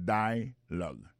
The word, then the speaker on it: dialogue: Alors, mot dialogue, ça c'est dialogue. Ça nous a remarqué là, nous a remarqué que l'accent tonique là tombé sous premier syllabe là.